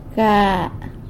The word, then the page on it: ga: sanlucasquiavini